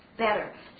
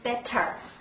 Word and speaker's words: better: Better，注意这个发音的时候，美式发音啊，它不是完全的送气的 T，不是 better 啊，发的时候比较的得，OK， better。